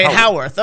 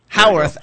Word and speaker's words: Howarth: Howarth, like Howard with TH on the end. – Okay, Howarth.